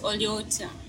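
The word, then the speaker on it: Oli otya: It's Oliotia. Oliotia? – Yeah, for one person.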